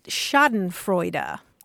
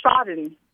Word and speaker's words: Schadenfreude: It's S-C-H-A-D-E-N-F-R-E-U-D-E. Schadenfreude. Yeah. – Okay, so we wrote the word, but it's Schaden. – Yeah, Schaden. Mm-hmm. Yeah, it retains something of the German pronunciation. And that E on the end is pronounced as a U.